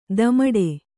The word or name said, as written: ದಮಡೆ